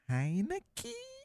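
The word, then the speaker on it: Heinicke: I know they – what's the proper pronunciation of the quarterback who played for them in the playoffs? Is it Heineke? – Yes.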